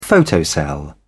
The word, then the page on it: photocell: British English